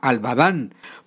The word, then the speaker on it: Albadán: Es agricultor de la localidad de Albadán, es un corregimiento del municipio de Gigante en el departamento del Huila, Albadán. Pronuncié bien este nombre, señor Valencia, muy buenos días, bienvenido al Campo de Caracol Radio. – Muy buenos días, sí señor, está bien pronunciado el municipio.